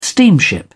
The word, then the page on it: steamship: British English